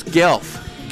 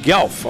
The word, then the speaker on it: Guelph: Looks like Gulip, Ontario. – Oh, that's Guelph. – Guelph, okay. See, Guelph.